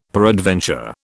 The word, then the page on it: peradventure: British English